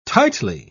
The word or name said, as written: tightly